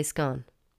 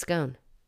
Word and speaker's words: scone: I'm first going to ask, does the following word rhyme with gone or bone? Can you guess what it is? Yes, it's scone. Some say scone. Or is it scone?